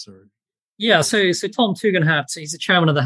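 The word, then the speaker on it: Tugendhat: Tom Tegenhat, am I pronouncing that one correctly? What's his story? – Tegenhat, yes.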